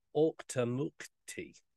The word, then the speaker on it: Auchtermuchty: So yeah, it's no longer like a pub or anything like that now, but it's in the town of Auchtermuchty, and that is the pronunciation.